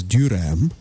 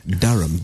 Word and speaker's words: Duram: Well, look, sometimes it's mispronounced as Durham, but we're getting it right on radio. Durham, D-U-R-A-M.